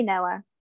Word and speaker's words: Noa: I say Noah. I think it's Noah. – I would say Noah.